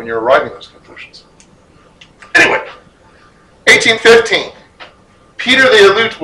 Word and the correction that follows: Aleut: was martyred, killed. According to Orthodox history, Aleut? Thank you. Well, because it's the Aleutians, I'd never heard. Thank you very much. I'd never heard it pronounced.